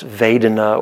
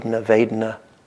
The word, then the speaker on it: Vedana: Second foundation, Bob mentioned briefly, but just very briefly, and it's called, if you're interested in Pali, it's Vedana, or it's the V's or a W pronunciation from a Burmese, either way, Vedana.